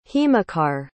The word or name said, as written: himakar